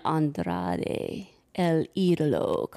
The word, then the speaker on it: Andrade El Idolo: So they had their fun, and then Andrade El Idolo comes out.